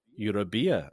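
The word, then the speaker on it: Euribia: They've just started their next, and this is quite an exciting one, MSC Eurobia, I think is how you pronounce it. – Yeah, I think that's right.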